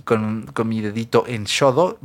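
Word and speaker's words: Xodo: Ah, se escribe X-O-D-O, -O, d o vale Así pone Shodo PDF.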